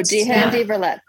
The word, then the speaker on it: Die Hand, die verletzt: Die Hand, Die Verletzt. – Oh, Die Hand, Die Verletzt.